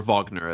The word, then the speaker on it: Wagner: Richard Wagner, or Wagner, as you would pronounce it in the German.